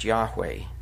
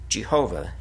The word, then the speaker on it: Yahweh: In the King James Version, wherever the words the Lord appear in small capital letters, it is in place of God's name, spelled YHWH in Hebrew, and probably pronounced Yahweh, Jehovah, in English.